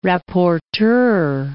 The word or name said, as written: rapporteur